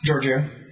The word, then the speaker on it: Giorgio: How's it pronounced? – Giorgio. It's Italian.